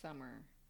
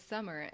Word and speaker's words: Samar: yeah, it's Summer. And it's spelled Samar. So people looking at it often say Samar.